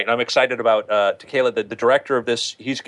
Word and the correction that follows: Taika Waititi: Taika Waititi, yeah.